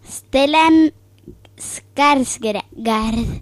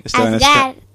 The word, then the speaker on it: Stellan Skarsgård: Stellan Skarsgård. – Perfecto. Pues sí, Stellan Skarsgård.